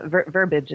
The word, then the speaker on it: verbiage: I also have thoughts on the pronunciation of Tom's word that you corrected, and verbiage is an acceptable pronunciation.